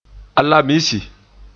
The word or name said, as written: Alamisi